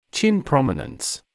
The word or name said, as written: chin prominence